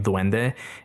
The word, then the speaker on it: Duende: So there's also some folklore of them in the Philippines, and they actually spell it differently. It looks like it's pronounced exactly the same, Duende. In Spanish, it's D-U-E-N-D-E. And in the Philippines, they spell it D-W-E-N-D-E. But it's the same pronunciation.